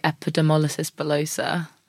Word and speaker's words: epidermolysis bullosa: People do pronounce it in different ways, yeah. – But I always say epidermolysis bullosa.